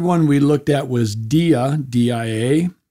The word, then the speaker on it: dia: Dia is like our word diameter, like a line running through a circle, comes from dia, and we all came out of God.